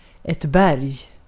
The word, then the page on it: berg: /bɛrj/